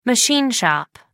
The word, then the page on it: machine shop: American English